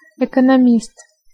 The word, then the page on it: ekonomist: Russian